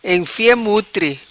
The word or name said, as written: Enfia mutri